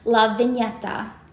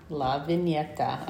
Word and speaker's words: La Vigneta: The way we spell it is Sicilian. – Okay, so. and it's pronounced La Vigneta. – La Vigneta.